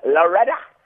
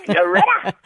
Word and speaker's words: Loretta: You must pronounce it properly. It's Loretta. Loretta.